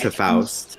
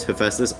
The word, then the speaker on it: Hephaestus: I thought it was Hephaust. I thought his name was Hephaust. – Mine is so much worse. – Okay. – What? – I thought it was like Hephaestius.